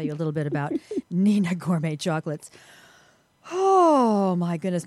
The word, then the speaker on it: Hnina: It's spelled H-N-I-N-A, but it's pronounced Nina.